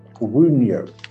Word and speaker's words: Wunjo: Wunjo. Wunyo. That's how you pronounce it.